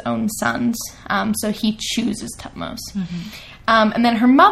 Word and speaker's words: Thutmose: It can also be pronounced Tutmose, but I'm sticking with Thutmose.